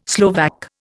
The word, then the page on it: Slovak: Indian